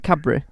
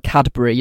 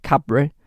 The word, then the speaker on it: Cadbury: I think everyone says it right, Cadbury. I don't pronounce the D, so people like Cadbury, you don't do that, it's like Cadbury.